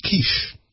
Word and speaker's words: quiche: Grandpa, it's pronounced quiche.